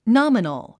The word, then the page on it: nominal: nάmənl; ナァメノォ